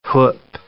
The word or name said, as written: whup